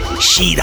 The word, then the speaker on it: Schirach: Well, I think he pronounces it Shida.